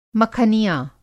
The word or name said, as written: मखनिया